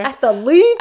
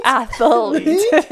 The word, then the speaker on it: athlete: Athlete. – I like how you goodness. Put that extra syllable in there. – Athlete. – Athlete.